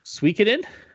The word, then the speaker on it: Suikoden: Suikoden? – Yeah. – That sounds right, actually. That sounds like that's how Moriarty pronounces it.